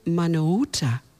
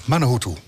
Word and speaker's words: Manuhutu: En we hebben, eh, ik ga het goed uitspreken, Johnny Manuhuta. Mahuta. Manuhuta. – Bijna goed, hè? – Oh, wat erg, Johnny.